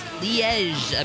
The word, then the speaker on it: Liège: It is Liège, apparently. Okay. And I love my pronouncer is specifically Liège, and you say the J sound at the end like Zha Zha.